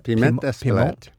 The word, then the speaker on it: piment d'Espelette: Piment d'Espelette. – Piment? French? – Well, in America, you say piment because we don't know how to do it. So I just call it espalette and very leave nice.